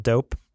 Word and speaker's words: Doughp: There's this place at Pier 39 in San Francisco called Dope, but it's spelled D-O-U-G-H-P, like cookie dough, dope.